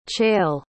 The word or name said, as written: chale